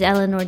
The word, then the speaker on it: Ellinor: My Twitch is Eleanor DeLorenzo. My YouTube is Eleanor DeLorenzo, and it's spelled E-L-L-I-N-O-R.